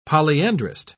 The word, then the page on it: polyandrist: British English